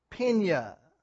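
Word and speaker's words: Pinya (πενία): And in the Greek, that's the word penia, P-E-N-I-A, if you want to spell it in English. Pinya.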